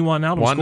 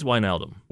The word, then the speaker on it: Wijnaldum: How are you saying it? – I think it's Wijnaldum. – Wijnaldum. Okay.